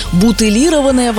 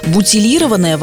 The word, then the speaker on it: бутилированная: То есть, по аналогии с бутылкой, бутылированная вода. Вот это категорически неправильно и вопреки всем литературным нормам. Бутилированная вода – это словарная литературная форма. Восходит она к французскому слову «бутиль» и сохраняет вот это вот мягкое произношение, мягкой «Т».